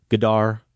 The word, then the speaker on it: Godard: Godard and 3D.